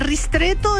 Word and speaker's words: ristretto: El ristretto está bien pronunciado.